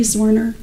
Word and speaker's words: Zwerner: Julie Zwerner, J-U-L-I-E-Z-W-E-R-N-E-R.